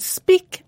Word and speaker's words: speak: And speak and break are pronounced differently, although they both have E-A-K at the end.